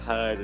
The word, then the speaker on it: Heard: Because her name is Amber Heard and Heard rhymes with Turd.